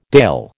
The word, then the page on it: dale: American English